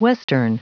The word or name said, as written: western